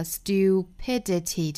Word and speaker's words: stupidity: 那倒数第三个音节就落在了 stupidity 这个 p 这块了，所以说倒数第三个音节 p 需要做重读，整个句子重读音节在 p, stupidity, stupidity.